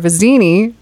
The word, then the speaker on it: Vizzini: For Vizzini, am I saying that right? – Yeah.